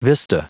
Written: vista

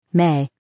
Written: may

